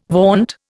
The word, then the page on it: vaunt: Indian